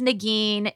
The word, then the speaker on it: Negin: my name is Negin. It's two syllables, but for whatever reason, it makes people's minds melt.